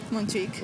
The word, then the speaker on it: Montjuïc: Montjuïc.